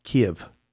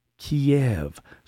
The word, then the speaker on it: Kyiv: and they keep calling it Kiev. – Yeah. – Kyiv. – Or Kyiv. – The capital, Kyiv. – Yeah. – Yeah. They don't know how to pronounce the freaking name of the capital of Ukraine.